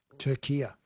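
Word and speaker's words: Türkiye: But in yesterday's Socceroos update, you kept saying Turkey and not – Turkia.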